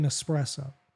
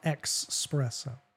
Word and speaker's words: espresso: It's silent, just like in espresso. So, such a prick. – It's a silent X in espresso. It's not expresso.